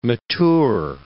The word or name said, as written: mature